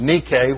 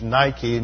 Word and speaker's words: Nike: The noun is Nike, which is mispronounced in English parlance as Nike, and you put it on a tennis shoe.